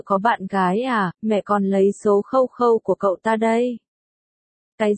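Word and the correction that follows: QQ: Cái này đọc là kiêu kiêu. 1. Vô văn hóa, ba cô ở bên cạnh chỉnh họng. 1. Kiêu kiêu, mẹ Diệp Phàm đã đọc sai kiêu kiêu thành Khâu Khâu.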